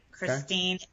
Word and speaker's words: Christine: Okay, so I do have a website, Christine H. Moore. So it's spelled C-H-R-I-S-T-I-N-E-H, and then Moore, M-O-O-R-E, .com.